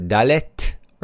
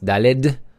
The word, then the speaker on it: Dalet: And let's move on to the letter Dalet, or sometimes it's pronounced Daled. So it's up to you. The correct pronunciation is Dalet, but Daled is also fine. It's accepted as well.